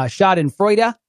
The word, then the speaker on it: Schadenfreude: And I know that uh, Schadenfreude.